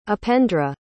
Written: upendra

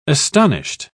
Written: astonished

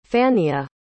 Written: fannia

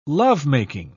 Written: lovemaking

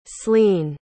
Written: slean

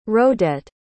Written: rhodette

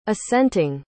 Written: assenting

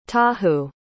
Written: tahu

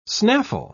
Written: snaffle

